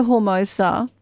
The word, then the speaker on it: Hermosa: Or it's a Spanish word. Hermosa, I guess. Yeah. Hermosa. Yeah, with a little roll of the R.